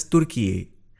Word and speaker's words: Türkiye: Turkey is now pronounced as Türkiye.